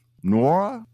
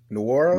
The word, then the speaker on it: Nwora: Nwora. – Nwora? – Nwora.